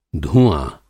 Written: धूआँ

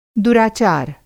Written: दुराचार

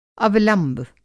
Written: अवलंब

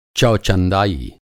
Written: चौचंदाई

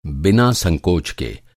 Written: संकोच

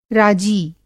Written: राजी

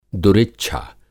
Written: दुरिच्छा

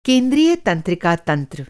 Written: तंत्रिका

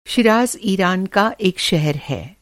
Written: शीराज़

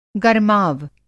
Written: गरमाव

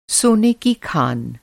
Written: खनिन्न

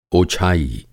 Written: ओछाई

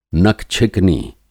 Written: नकछिकनी

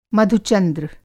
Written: मधु-चंद्र

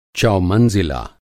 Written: चौमंज़िला

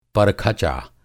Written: परखचा

